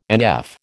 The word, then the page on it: NF: American English